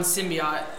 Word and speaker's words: symbiote: Well, there's been rumors already about it referencing more than one symbiote.